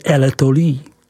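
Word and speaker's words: L'Étoile: Čtvrtou podoblastí je L'Etolie, vyslovuj L'Etolie.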